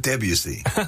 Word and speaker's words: Debussy: Debussy prelude. Did I say that right? – Debussy. Am I butchering it too? – Debussy.